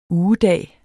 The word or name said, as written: ugedag